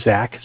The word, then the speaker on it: Zach: So my name is Zach, spelled Z-A-C-H.